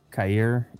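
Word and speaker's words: Kaiir: Kair Elam.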